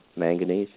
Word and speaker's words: manganese: It's going to be 15-0-15, and then I need some zinc, and I need some, I guess I'm pronouncing it, manganese? – Uh-huh, manganese, yeah. Well, I would say low quantities on those two, so.